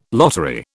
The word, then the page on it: lottery: British English